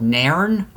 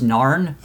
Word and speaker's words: Nairn: It was a small place called Nairn, but I've also heard it pronounced as Narn, okay. – N-A-I-R-N, at least to my knowledge.